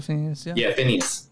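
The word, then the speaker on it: Finneas: In fact, John Mayer introduced him to Finnis O'Connell, Billy Eilish's brother, who did the original score for this movie. – Yeah, Phineas. – I knew I said that wrong.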